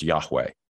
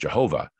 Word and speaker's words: Yahweh: it was a J-H-W-H But we now believe that the consonants were A and E, and it would be pronounced Yahweh. Mm-hmm. But early on, before we got some of those better understandings, it was thought, well, it's pronounced Jehovah. And it's not. It's Yahweh is the closest that there is.